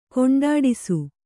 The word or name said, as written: ಕೊಂಡಾಡಿಸು